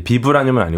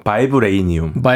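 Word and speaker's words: vibranium: 그래서 이게 가상의 금속이고 실제 존재하는 금속은 아닌데 영어식 발음이 이제 비브라늄은 아니고 바이브레이니움. 바이브레이니움. 네, 바이브레이션에서 온 거라서 이 금속의 주요 특성이 진동하는 겁니다.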